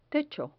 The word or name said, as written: techo